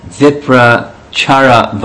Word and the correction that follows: Uparichara: That's not the right way to say his name. Uparichara Vasu.